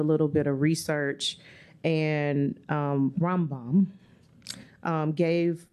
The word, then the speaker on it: Rambam: He's known by an acronym because his name is so hard to pronounce. – I saw that. – R-A-M-B-A-M. He's called the Rambam.